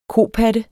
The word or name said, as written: kopatte